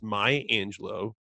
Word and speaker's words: Maya Angelou: Maya Angelou has a U on the end, but it's Maya Angelou.